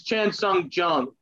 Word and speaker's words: Chan Sung Jung: It's Chan Sung Jung.